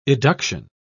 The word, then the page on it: eduction: British English